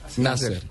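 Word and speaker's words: Nasr: ¿cómo se pronuncia? – Nasser. Felipe Nasser, qué, qué buen corredor.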